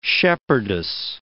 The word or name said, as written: shepherdess